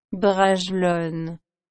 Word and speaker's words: Bragelonne: Bragalón. Como soy un desastre pronunciando nombres extranjeros, especialmente si estos son en francés, le he pedido a Siri que me corrija. Bragelonne. Gracias, Siri.